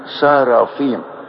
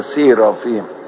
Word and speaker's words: سارافيم: على فكرة اسمها سارافيم، في ناس كتير ينطقوها سيرافيم، هو مش سيرافيم، سارافيم اسم جمع ومفرده ساراف، وكاروبيم أو شاروبيم اسم جمع ومفرده كاروب.